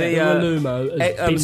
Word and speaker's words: Iwelumo: How did you pronounce that? – Iwelumo? Chris Iwelumo. – Yeah, Iwelumo.